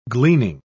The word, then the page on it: gleaning: British English